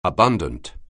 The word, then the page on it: abundant: anglais